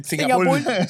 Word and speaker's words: Singapur: Es que la primera vez sonó Singapur. – Singapur. Singapur. Con R al final.